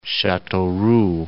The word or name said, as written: Chateauroux